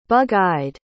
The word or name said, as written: bug-eyed